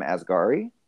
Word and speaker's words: Asghari: And um, from what I hear, her husband, Sam Asghari, I think is how you pronounce his last name.